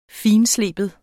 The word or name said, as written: fintsleben